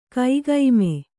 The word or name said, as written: ಕೋಕಮ